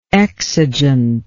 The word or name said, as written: exigently